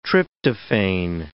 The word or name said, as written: tryptophane